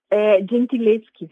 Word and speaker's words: Gentileschi: Você está falando da Artemisia Gentileschi. Eu não sei se eu estou pronunciando certo. O Gentileschi, é isso mesmo? – É, Gentileschi, seria.